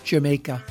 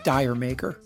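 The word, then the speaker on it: D'yer Mak'er: First, the song title, which is never mentioned in the song, should be pronounced Jamaica. Yes, for real, Jamaica, or Did Jamaica, but not Dire Maker.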